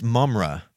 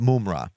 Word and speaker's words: Mum-Ra: No, guys, it's Mum-Ra. Mum-Ra. – Mum-Ra. – Mum-Ra. That's how you say it classily, is Mum-Ra.